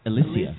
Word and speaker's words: Alicia: I believe it's pronounced Alicia.